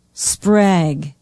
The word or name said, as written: sprague